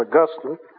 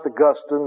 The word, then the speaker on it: Augustine: That's the quote I just read to you from Augustine. And by the way, it's pronounced Augustine, not Augustine. Saint Augustine is in heaven, and Saint Augustine is in Florida.